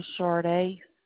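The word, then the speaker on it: Charde: My first name is Sharday, C-H-A-R, D as in dog, E. And my last name is Lundy, L-U-N, D as in dog, Y.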